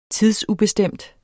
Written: tidsubestemt

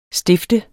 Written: stift

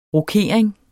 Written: rokering

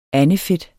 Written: andefedt

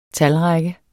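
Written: TALRÆKKE

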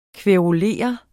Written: kværulere

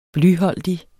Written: blyholdig

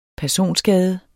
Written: personskade

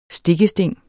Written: stikkesting

